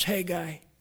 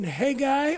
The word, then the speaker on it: Haggai: Now, I did look up how to pronounce Haggai. And you can Haggai or Haggai. So if I go back and forth, it's because I know it's okay.